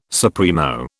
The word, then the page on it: supremo: British English